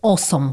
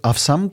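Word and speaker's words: awesome: Niezastąpiony przymiotnik w ustach Harvey Spectera, i uwaga na wymowę, mhm. tak? Czyli to musi być takie krótkie osom. – A jak ja bym powiedział teraz awsam, to wiesz, że połowa Warszawy będzie chodziła z tym awsam.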